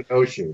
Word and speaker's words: Kenosha: And let's say if he goes to Kenosha, am I saying that correctly? Wisconsin? – Yeah, Kenosha, right.